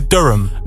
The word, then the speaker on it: Durham: I would say Durham.